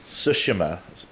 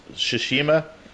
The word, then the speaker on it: Tsushima: Yeah, I'm not trying to mispronounce this word. I don't really know how to say it. It's Tsushima. Tsushima. – It's Ghost of Tsushima.